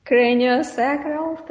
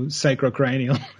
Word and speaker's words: craniosacral: I'd like to move on and ask you about craniosacral therapy. Am I pronouncing it correct? – Yep. You're getting them the right way around. A lot of people go sacrocranial.